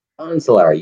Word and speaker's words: Bonzelaar: Bonzelaar, you got it.